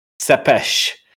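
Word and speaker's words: Țepeș: Remember, it's pronounced Sepesh.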